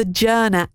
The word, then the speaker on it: Journa: Search for the Journa app. Now, it's spelt like journal, but without the L at the end, Journa, to take your devotions, prayer and reflections with you in one handy app.